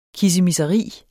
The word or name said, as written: kissemisseri